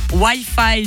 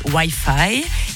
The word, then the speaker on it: Wi-Fi: Parce que je vous ai dit, donc la stat, dans le monde, nous sommes 92% à prononcer Wi-Fi et les 8% restants prononcent Wi-Fi. Je vous avais donné ça comme deuxième indice. Je vous ai dit, les 8% sont des Français.